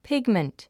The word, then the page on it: pigment: pígmənt; ピグマント